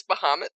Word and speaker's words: Bahamut: It's Bahamut.